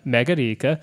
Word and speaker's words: Megarika: Um, Megarika, you have to say it like that. It's a Swedish word.